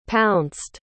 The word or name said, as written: pounced